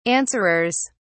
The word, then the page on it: answerers: British English